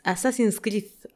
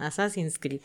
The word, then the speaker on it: Assassin's Creed: Ya me están corrigiendo aquí la pronunciación. Assassin's, Assassin's Creed. Assassin's, ok, perdón, Assassin's Creed.